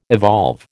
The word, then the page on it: evolve: American English